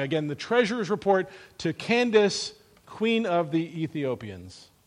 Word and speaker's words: Candace: Apparently, that word that we pronounce Candace, frankly, we Americanize it, one should be pronounced Candace. I'm going to sing this song the way the author intended it, but if we're going to be real, it's actually pronounced Candace, and it's a word that indicates, it's like a title for the Queen of Ethiopia.